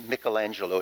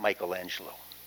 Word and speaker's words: Michelangelo: Us art snobs say it right. It's Michelangelo. It's not Michelangelo.